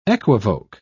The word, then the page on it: equivoke: British English